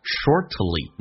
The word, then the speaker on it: shortly: 这个 soon after 就相当于啊，我们讲 shortly 那个 t 可以不用发音，叫 shortly after 或者 immediately after，这两种方式都是可以，相当于等同于 soon after。